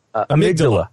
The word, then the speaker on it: amygdala: Amygdala.